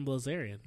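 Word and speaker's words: Bilzerian: Dan Brazilian. That lifestyle. What a goat. That's the lifestyle. – You know, we're not even saying his name right. – Dan Blazarian.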